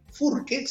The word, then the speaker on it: Furque: Así que ponen en Google Miguel Furque, se escribe con Q de queso, eh, ponen Miguel Furque y